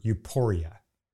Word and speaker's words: euporia: But another, not so obvious, involves the Greek word euporia. That's E-U-P-O-R-I-A, euporia. And by the way, pardon my Indiana accent as I pronounce the word.